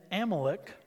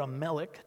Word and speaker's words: Amalek: Then Amalek, or Amalek, depending on how you say it, then Amalek came and fought with Israel at Rephidim.